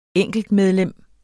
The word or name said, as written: enkeltmedlem